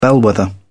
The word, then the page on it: bellwether: British English